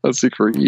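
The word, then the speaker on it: Zicree: Oder vielleicht auch beabsichtigt, keine Ahnung, weil äh, Mark Scott Secree, also auf, den, auf die Aussprache haben wir uns jetzt ja geeinigt, mhm. glaube ich. Ne? ja, genau. Secree.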